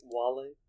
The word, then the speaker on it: Wale: Or Is There Any Love with Whale? – Yeah, it's pronounced Wale.